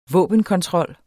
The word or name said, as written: våbenkontrol